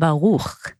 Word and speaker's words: Baruch: Okay, so basically my name is Meital Baruch, but I find it very hard for Asian to pronounce the kh.